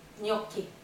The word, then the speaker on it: gnocchi: Och det uttalas inte så. Det uttalas gnocchi. Gnocchi.